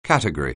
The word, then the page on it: category: anglais